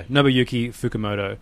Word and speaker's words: Nobuyuki Fukumoto: There's a whole, like, I found like a community of people who translate all of Nobuyuku. Am I even saying his name right? It's so hard just remembering all the characters. Yeah, Nobuyuki Fukumoto.